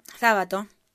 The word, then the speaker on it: Sábato: Pronúnciese Sábato, puesto que fue el escritor en Ernesto Sábato quien presidió la comisión que lo entregó el 20 de septiembre de 1984 al entonces presidente Raúl Alfonsín.